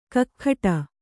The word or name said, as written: ಕಿಗ್ಗಡೆ